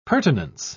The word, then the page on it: pertinence: British English